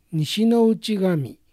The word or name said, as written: Nishinouchigami